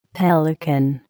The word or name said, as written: pelican